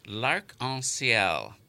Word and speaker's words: L'Arc-en-Ciel: Yes, and now the director of L'Arc-en-Ciel. – Excellent. You pronounce it prononcé parfaitement.